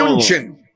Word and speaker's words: München: München.